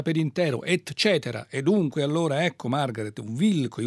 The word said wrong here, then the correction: Wilco: Wilco, questo è Jesus, eccetera, da un disco del 2003 che si chiama Yankee Hotel Foxtrot.